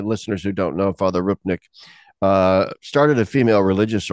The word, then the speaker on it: Rupnik: I think that's like uh, Rupnik or Rupnik. I'm not certain how, how do you pronounce it, Chris? Do you know? Uh, yeah, I believe it's Rupnik.